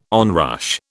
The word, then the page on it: onrush: British English